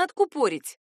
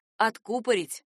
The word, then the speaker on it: откупорить: Правильное ударение в глаголе «откупорить» легко проверить с помощью известной цитаты из произведения Пушкина. «Откупори шампанского бутылку, иль перечти женитьбу Фигаро». Правильно именно так — «откупорить» и «откупоривать».